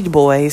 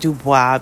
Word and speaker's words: Du Bois: Du Bois, some pronounce it Dubois because that's the French way, but he was African-American, he was from the 20th century, and he pronounced it in an Americanized way. Italians did the same thing. Many people did the same thing. So I'm going to pronounce it the way he pronounced it.